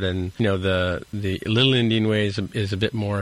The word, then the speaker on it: endian: He posted an article here talking about memory addressing, and, you know, it gives a definition of big Endian and little Endian. I don't know if I'm saying that right or wrong. – No, that's right, yep.